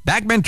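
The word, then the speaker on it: Bachman: Jim 99 and 100, Randy Backman, who was originally in the Guess Who, and Fred Turner, Backman Turner Overdrive. Notice I say Backman because that's how he pronounces it.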